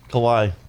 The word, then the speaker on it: Kauai: Kauai, I guess it's pronounced? – Kauai. – Oh, Kauai. Okay, thank you.